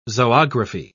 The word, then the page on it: zoography: British English